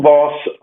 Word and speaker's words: Vause: And you're Carl with a C, C-A-R-L, and your last name is spelled V-A-U-S-E. – Yes, Carl Voss.